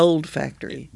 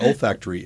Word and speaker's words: olfactory: Not old factory. – Right. But isn't that interesting? – Not new factory. – Now, in Oklahoma, they might pronounce it old factory. – Old factory. – My old factory's acting up. – My old factory's not working now. – Yeah. My sniffers ain't sniffing. Yeah. But olfactory.